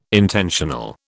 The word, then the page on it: intentional: British English